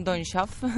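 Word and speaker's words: Don Chaffey: Empezamos con Hace un millón de años, del director, a ver si lo digo bien, Don Schaff. Bueno, está muy bien, está muy bien pronunciado.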